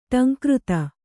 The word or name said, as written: ಢಂಕೃತಿ